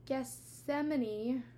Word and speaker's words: Gethsemane: Jesus was he he was in the garden of Gethsemane, I always say it wrong. Garden of Gethsemane. Yes, that's it.